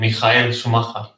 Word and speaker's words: Michael Schumacher: Un placer estar aquí con vosotros y bueno, a, a ver qué tal va el podcast sobre Mijael Schumacher.